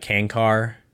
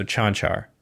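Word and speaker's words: Čančar: It's always, I mean, it's usually like Kankar, obviously, because it's C-A-N-C-A-R, but he's from Slovenia. So the C's are soft. Is that what you would call them? Because that's three. I'm thinking of three sounds for a C, obviously, with the S sound. In English, that's probably more of the soft C. Regardless, the ch sound for a C. So Chanchar.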